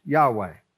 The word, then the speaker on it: Yahweh: Pronounced Yahweh. That's how he's known.